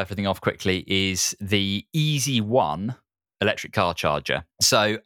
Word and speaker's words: Easee: Easy, so EASEE is the way it's spelled, are a Nordic company.